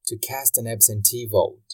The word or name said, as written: to cast an absentee vote